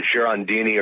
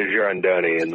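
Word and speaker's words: Girandoni: The Girondini rifle? – There's some debate among everyone about whether it's Girondini or Girondoni. But I'm calling it the Girondoni in the book.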